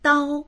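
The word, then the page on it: dao1: Mandarin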